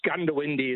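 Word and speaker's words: Goondiwindi: Why do you say Gundawindi the way it's pronounced locally? – No, it's Gundawindi is correct.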